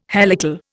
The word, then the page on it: helical: Indian